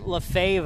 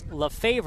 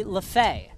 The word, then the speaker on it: LeFaivre: Jackson LaFay, by the way, his last name is spelled LeFAivre, which if you look at it, you think it's LaFavre or LaFavre, but it's actually LaFay.